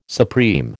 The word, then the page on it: supreme: American English